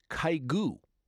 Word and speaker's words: Kygo: That's his real name, but he goes by Kygo. However, if you are in Norway, he's known as Kygo, which is different, but that's how it's pronounced in Norwegian.